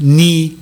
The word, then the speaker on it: knee: Ama İngilizce'de K'yi yok sayıyorlar biliyorsun. – Ha, kibar toplum belki ondan. K yok mu? Eğer kelimenin başındaysa hiç telaffuz bile edilmiyor. – Ha, night. – Night, no, knife. Do you know? Knife, knee.